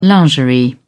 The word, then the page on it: lingerie: British English